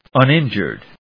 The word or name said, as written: uninjured